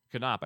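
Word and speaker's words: Knoop: Hope you enjoyed the Bobby Canoop interview. Knopp, I should say. My gosh, I just said his name wrong.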